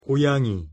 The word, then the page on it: 고양이: Korean